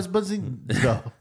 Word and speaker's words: Esposito: Get his name right. Giancarlo Esposito.